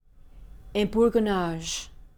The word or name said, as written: ebourgeonnage